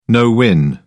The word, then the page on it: no-win: British English